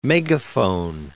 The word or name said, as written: megaphone